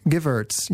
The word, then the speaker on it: Givertz: Givertz, yeah. Givertz. yes. With a, with a Z.